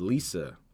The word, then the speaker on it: Lesia: My mentor who got me into the mortgage world, the mortgage business, her name is Lisa, right? – Mm-hmm. – And, you know, her name is spelled L-E-S-I-A. When you hear that, that don't look like Lisa or smell like Lisa, right? – No.